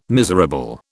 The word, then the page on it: miserable: British English